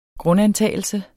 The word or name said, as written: grundantagelse